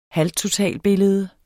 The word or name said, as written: halvtotalbillede